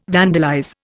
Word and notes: vandalize: Indian

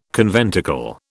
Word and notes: conventicle: British English

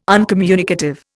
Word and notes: uncommunicative: Indian

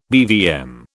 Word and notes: BVM: British English

